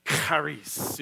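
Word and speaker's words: charis (χάρις): The word joyful here is actually, the root of it is the Greek word charis, or if you want to say it properly, and you can do this with me, charis. You know, get that sound in there from the Greek.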